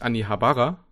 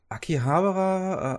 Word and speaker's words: Akihabara: Vor allem, ich sag eigentlich Anni Habara, aber das ist ja eigentlich auch von der japanischen Aussprache ist es falsch. Eigentlich ist deine Aussprache ja die richtige. Aki, Akihabara, äh, Aki.